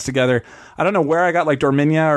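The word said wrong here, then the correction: Dominaria: It's Dominara. I think that's how you pronounce it. There were several more syllables and vowels than I gave it credit for.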